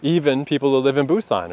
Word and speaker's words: Busan: But the thing that did surprise me is he was saying how the second biggest city, Busan, which is where I learned that he pronounces it Pusan, I mean, it's still pretty big.